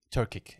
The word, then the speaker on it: Turkic: Turkic. – Turkic, exactly.